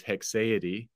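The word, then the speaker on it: haecceity: Nevertheless, hexaity is an English word, yeah. – and the most common way to pronounce that word is hexaity.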